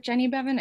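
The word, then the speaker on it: Jenny Beavan: I hope I'm saying her name right. Is it Jenny Bevan? I think it is. – I think so. Yeah.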